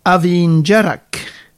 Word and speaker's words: Abhainn Dearg: det här som uttalas Avin Avinjerak. – Just det. – Men eh, eh, skrivs Abhain Dearg.